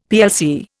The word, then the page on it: plc: Indian